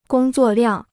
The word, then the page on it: 工作量: gōng zuò liàng